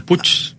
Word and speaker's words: puig: Sí, que se escribe puig, ¿no? Puig, y ¿no? Se pronuncia push.